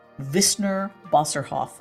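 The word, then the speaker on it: Wissner-Bosserhoff: In today's Innovation Spotlight, I'm speaking with Uwe Deckert of the German firm Wissner Bosserhoff. I did pronounce that correctly, didn't I? – You did absolutely correct.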